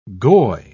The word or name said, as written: goy